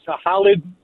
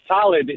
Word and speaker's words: Halid: His name was Khalid. That's how you pronounce it. But, you know, he's like a country singer in our country. – Really? His name was Halib? – Yeah. Khalid. It's like H-A-L-I-D.